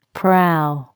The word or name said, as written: prow